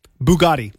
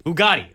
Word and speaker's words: Bugatti: Bugatti. – Bugatti. Okay. So let me tell you what I know about the Bugatti... Chiron? – Citron. – It's not Citron. It's Chiron. – Chiron? – It's the Bugatti Chiron.